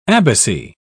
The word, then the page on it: abbacy: British English